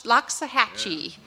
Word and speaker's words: Loxahatchee: Gordon Gentry is the head performer, and they are from Loxahatchee. Did I say that right? – Loxahatchee.